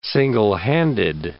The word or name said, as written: single-handed